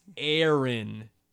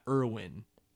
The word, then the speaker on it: Erin: It's Aaron, E-R-I-N, not Irwin, E-R-I-N.